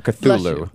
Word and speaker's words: Cthulhu: Cthulhu. Wait, I just said it wrong. Cthulhu. There we go. yes. – Sorry. – It's a hard C at the Cthulhu. – Beginning and then just Thulu.